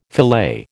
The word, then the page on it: filet: American English